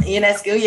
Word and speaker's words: Ionescu: I'm thinking of how I'm going to butcher her last name, Sabrina Ionescu. – Mm-hmm. Ionescu, yep.